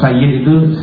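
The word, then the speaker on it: Sayyidi (سيدي): Nah, kalau Sayyid itu Sidi, pakai D gitu ya. Sidi. Aslinya tuh mestinya bacanya bukan Sidi, tapi Sayyidi.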